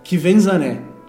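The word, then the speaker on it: Quvenzhané: Eu vi uma matériazinha que ela explica como é que fala o nome dela. O nome dela é Kvenzané, tá?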